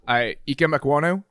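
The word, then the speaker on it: Ikem Ekwonu: Ike Mekwonu, is that how you pronounce it? – Yeah, it was close. Good. Pretty close.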